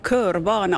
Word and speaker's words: Körvana: Ja, och faktiskt jag måste rätta ditt uttal lite, för att det är ju en ordlek och det här det skrivs precis så där som du säger. Men jag tror att ni som sitter här nu, kan ni säga, när jag räknar till tre, kan ni säga hur man ska uttala det här? 1, 2, 3. – Körvana! – Ja, då hade jag rätt,